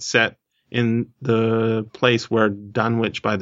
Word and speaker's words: Dunwich: Or how do we pronounce it? – It's Dunwich. I'm fairly... – Dunwich? – Yeah. – I like saying it with a witch, because then we get the witch idea in our heads.